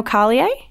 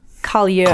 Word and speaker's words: Carlier: Oneil Carlier? – Carlier. – Collier. – Collier. – Car.